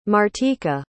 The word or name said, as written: martika